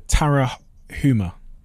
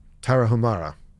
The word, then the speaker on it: Tarahumara: the Tarahuma. – Tarahumara.